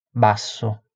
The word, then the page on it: basso: Italian